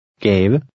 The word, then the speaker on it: Gave: In number 3, the V and E are pronounced. Try to read number 3. – Gave.